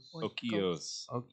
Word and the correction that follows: Oikos: Okios. – I don't know. – It's Oikos.